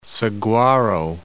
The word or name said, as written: Saguaro National Park